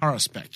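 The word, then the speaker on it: Haruspex: Haruspect, yeah.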